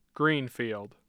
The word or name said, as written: Greenfield